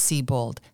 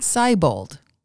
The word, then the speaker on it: Siebold: If you realize that his name is German, then you know it's pronounced Siebold, not Siebold.